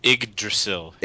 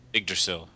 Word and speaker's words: Yggdrasil: I think it's Yggdrasil. – Yggdrasil? – Yggdrasil. – Okay.